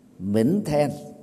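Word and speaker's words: Minh Thiên (明天): Minh thiên á, trong tiếng Bạch Thoại á, được phát âm là Minh Thiên, có nghĩa đen là ngày mai.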